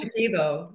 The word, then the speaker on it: Shipibo: Now I'm going to, how do you pronounce this? Shipibo? – Shipibo, yeah. – Not bad.